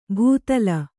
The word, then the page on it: ಭೂತಲ: bhūtala